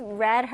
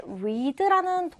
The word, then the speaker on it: read: 원래 이 동사의 원형은 네, read라는 동사죠. 현재형으로는 우리가 read, 읽다라고 하고요. 스펠링은 똑같아요. 그런데 read라고 읽지 않고 과거형은 어떻게 읽는다? read라고 읽어요. 아시겠죠? 발음에 주의하셔야 됩니다. read, read.